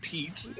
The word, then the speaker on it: Piet: Yeah, it's Pete. I mean, it's just like the U.S. name.